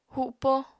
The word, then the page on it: hupo: apatani